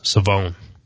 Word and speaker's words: Shavon: So, Philip, did you know that Shavon Revel was actually pronounced Shavon? – I knew I had been told that the H wasn't there, so I did know it was Suh. I didn't know there was so much emphasis on the O at the end. I just thought it was Shavon, not Shavon.